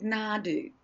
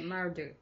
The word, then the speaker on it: Nardoo: So he believes there was a millet, an oat, but there was another one called nadu. There was also kangaroo grass, but there was this one called nadu. – How do you spell that? – N-A-R-D-O-O. – Okay, Nardu.